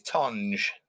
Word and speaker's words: Tonge: Is it Tong or Tonga? It's, it's Tonge.